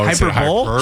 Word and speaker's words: hyperbole: Hyperbole. People say that. – Oh, hyperbole? – Hyperbole. Yeah, hyperbole. – That's how it's spelled.